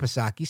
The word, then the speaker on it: Psaki: But Jen Pasircle-back Psaki, silent P, she thinks that it's absolutely necessary.